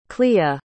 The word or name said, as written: clea